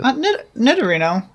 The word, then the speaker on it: Nidorino: I don't think Nidorino is the way to say it, but I like it. – Nidorino. You're just putting a little more emphasis in it, but I don't think you're necessarily pronouncing it wrong.